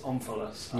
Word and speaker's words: omphalos: Omphalos. I don't know how to pronounce it. How would you pronounce it? – I pronounce it omphalos.